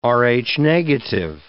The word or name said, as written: Rh-negative